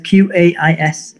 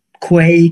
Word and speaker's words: Qais: What's the worst you've ever heard it pronounced? – You know what? Over the years, as you can imagine, both in different countries, I've heard stuff like Quay, Key.